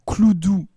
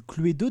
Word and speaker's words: Cluedo: Quatrième cas, même une fois que le titre est fixé, sa prononciation peut faire débat. Ainsi, entend-on parler du Cloudou et du Cluedo des époux Anthony et Elva Pratt,